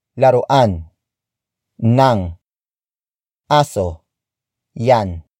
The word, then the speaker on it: aso: Aso. Aso. Aso is spelled as A-S-O.